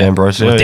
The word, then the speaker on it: D'Ambrosio: D'Ambrosio, is that how you say it? Yeah. D'Ambrosio. – D'Ambrosio, apparently.